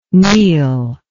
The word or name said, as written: neal